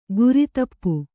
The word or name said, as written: ಗುರಿತಪ್ಪು